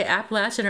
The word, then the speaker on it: Appalachian: He had apparently mentioned that he might be going to take some time to write or possibly hike the Appalachian Trail. I never know whether to say Appalachian or Appalachian, because in Pennsylvania it's Appalachian, and in the South it's Appalachian.